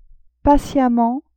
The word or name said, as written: patiemment